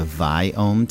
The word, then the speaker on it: Viome: is called a Viome test. It's spelled V-I-O-M-E.